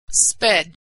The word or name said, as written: sped